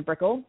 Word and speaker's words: Brickell: So my name will become Diana Brickell, legally. That's B-R-I-C-K-E-L-L.